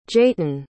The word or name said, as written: jaetyn